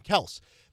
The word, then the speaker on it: Kelce: It has always stuck out to me that Andy Reid doesn't pronounce the E when talking about Travis Kelce. He calls him Kelce. The other day I watched a video where Mahomes did the same thing, called him Kelce instead of Kelce.